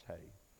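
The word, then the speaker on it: hey (ה): It looks like he, but I believe it's pronounced hey.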